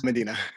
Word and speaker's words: Modinat: My name is Medina. It looks like Modinat, but it's pronounced Medina.